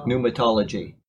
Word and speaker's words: pneumatology: Begins with the P and ends with pneumatology. Yes, and then the P is silent, by the way.